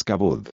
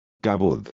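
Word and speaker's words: kavod (כבוד): Esa palabra es kavod. Kavod. Kavod es la forma en que se pronuncia.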